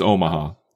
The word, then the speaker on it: Omaha: That's how it's pronounced. I've been pronouncing it Omaha for like years. – No, it's Omaha. Don't listen to him.